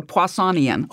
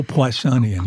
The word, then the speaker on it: Poissonian: And this is one of those communication issues that the scientists sort of assume you understand what Poissonian random distribution about a rate means. – I can't even pronounce it. What is it again? say it again. – Like Poisson is a Poissonian. – Oh, Poissonian. Yeah, right.